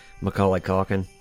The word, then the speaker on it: Macaulay Culkin: Macaulay Culkin.